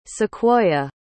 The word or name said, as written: Sequoyah